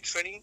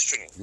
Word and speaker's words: Trini: But like, even if they can't pronounce that, I'm like, yo, just call me Trini. Same five letters is just easier to pronounce because of, you know, Trini. You don't have to make any accent or anything like that to pronounce my name.